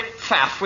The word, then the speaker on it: Pfaff: I'm Aubrey Pfaff with two small F's and a silent P.